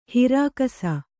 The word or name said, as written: ಹಿರಾಕಸ